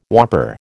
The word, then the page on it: whopper: American English